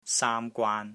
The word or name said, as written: SAAM1 GWAAN1